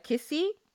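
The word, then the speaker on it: nkisi: So these charms, they are called kisi. It's literally spelled N-K-I-S-I. I think the N isn't pronounced, meaning an object within which a spiritual object dwells.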